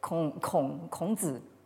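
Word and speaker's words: krongzi (孔子): Alors, c'est même pas la peine de connaître le chinois pour s'apercevoir que le mot zhu, euh, n'a rien à voir avec le nom de Confucius, qui se prononce en, chinois euh, krongzi.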